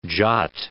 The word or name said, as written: jot